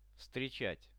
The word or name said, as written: vstrechat